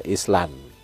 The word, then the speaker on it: Island: Tulisnya Island ya, Island. Island Industrium. Tapi bahasa Inggris kan bacanya kan agak beda. Tulisnya itu bacanya juga lain gitu. Tulis lain, baca lain.